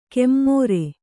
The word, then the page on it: ಕೆಮ್ಮೋರೆ: kemmōra